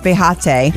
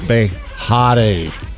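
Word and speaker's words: Behati: You said her name wrong. It's Behate. – Behate. – Behate. – Hot is in there.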